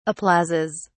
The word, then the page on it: applauses: British English